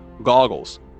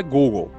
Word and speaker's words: goggles: Goggles. Não, não é Google, é goggle.